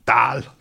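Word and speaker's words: dal: Det udtales Dahl, Thomas.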